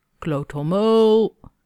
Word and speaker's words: kloothommel: Kloot with an O like road, hommel with an O like bomb. Extend the last E for emphasis. Kloothommel.